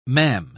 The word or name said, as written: mam